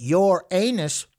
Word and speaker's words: Uranus: You look like you're from Neptune, but you smell like Uranus. But you should pronounce it your anus for comedic effect.